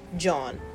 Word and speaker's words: jawn: J-A-W-N. John. It's like yawn, but with a J.